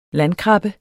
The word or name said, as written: landkrabbe